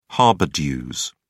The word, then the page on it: harbour dues: British English